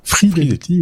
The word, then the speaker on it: Fidelity: et eh bien en fait euh, la, société Fidelity qui est Fidelity, voilà voilà pour prononcer correctement,